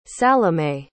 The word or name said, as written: salome